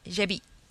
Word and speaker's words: Zsebi: And it's spelled Zsebi, and it's pronounced Jebi.